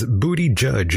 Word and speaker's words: Buttigieg: In the chat room, Brooke says, Booty Judge is how you say it.